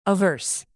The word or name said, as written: averse